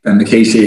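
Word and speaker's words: Micciche: Is it Dan Michici? – Dan Michici, yeah.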